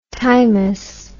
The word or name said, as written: timous